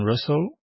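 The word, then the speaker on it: Rustls: And so you started working on Russell. That's how you pronounce it, right? Because I was at RustTLS.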